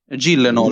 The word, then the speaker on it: Gyllenhaal: Gillenol.